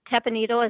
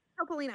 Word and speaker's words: Topolino: So the first meal we ate there was that, that breakfast at, Riviera Tepanito. Is that, how do you, is that how you say it? – Topolino.